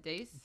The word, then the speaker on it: dais: How do you say it? Dais? – Dais.